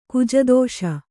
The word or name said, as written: ಕುಜದೋಷ